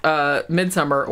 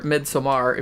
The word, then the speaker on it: Midsommar: Thematically, it's kind of similar, but basically with the story of Midsommar, or Midsommar, if you want to pronounce it correctly, what the story with that is it's almost like, it's long, it's 2.5 hours, and it's real...